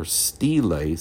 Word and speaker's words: stele: In 1901, the Morgan's men uncovered 3 fragments of black diorite, which then fitted together, formed an impressive round-topped stele, or stele, some pronounce it, monument some 7 feet, 5 inches high, and proved to be the laws of Hammurabi.